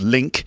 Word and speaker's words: LingQ: That's L I N G Q, pronounced Link.